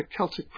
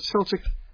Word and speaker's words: Celtic: By the way, if you pronounce it Celtic and you are in Northern Ireland or Scotland, they will correct you and say, no, it's Celtic. It might be spelled with a C, but it's pronounced with a K.